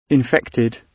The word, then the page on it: infected: British English